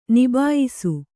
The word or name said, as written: ನಿಬಾಯಿಸು